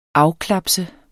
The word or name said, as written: afklapse